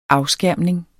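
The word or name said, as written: AFSKÆRMNING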